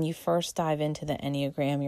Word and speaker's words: Enneagram: And the Enneagram is, let me spell it for you as well first. It's E-N-N-E-A-G-R-A-M, Enneagram.